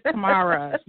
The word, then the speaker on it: Tamara: No, you're actually saying it right. You're like the only person who's ever said it right. So it is Tamara.